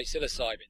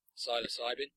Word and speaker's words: psilocybin: I always say psilocybin, but you can say psilocybin. Most people probably say psilocybin. – Okay, psilocybin it is. – There's a few people who even say psilocybin.